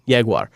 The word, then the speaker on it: Jaguar: That's how you say it. Jaguar.